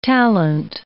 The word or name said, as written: talent